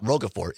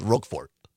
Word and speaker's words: Roquefort: It's not Roquefort. It's just Roquefort. Oh. I don't know French. Je ne sais quoi? Roquefort. Oh, Roquefort.